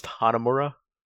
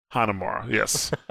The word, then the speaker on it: Hanamura: You know, I so believe it's pronounced Hanamura. Hanamura, yes. One thing for everybody that's listening to this podcast, I'm terrible with names.